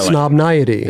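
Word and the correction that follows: Highsnobiety: Like, no, Snobiety. – Yeah, Snobiety. – He said it right. – Did he say it right? The very first time.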